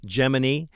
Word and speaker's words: Gemini: You've got Mercury, Gemini, which is the correct pronunciation, by the way, for everybody that thinks I'm saying it wrong.